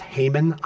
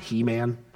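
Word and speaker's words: Heman: One was written by, I think his name is pronounced Haman. I really want to pronounce it He Man.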